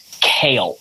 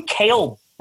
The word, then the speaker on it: Kalle: And people always, his name is K-A-L-L-E, last name Beck, B-E-C-K. People always mispronounce his first name because what kind of name is Kale?